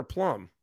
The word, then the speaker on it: Plum: Is it Plume or Plum? – I don't know. P-L-U-M. However the pencil tuckians pronounce it. – Plum, Plume, something like that. I don't know. It's a five-letter. Sorry. No, it's four. It's Plum, it's P-L-U-M. – It's four. Plum. Yeah, okay.